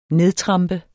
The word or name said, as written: nedtrampe